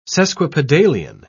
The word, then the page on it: sesquipedalian: British English